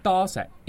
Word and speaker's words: Toe Shek: Pronounced Thaw Set in Cantonese, it translates to many rocks.